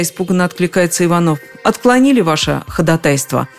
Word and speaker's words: ходатайство: Ходатайство. Таково правильное произношение этого слова.